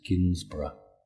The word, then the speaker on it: Ginsburg: I believe we pronounce her name as Ginsburg.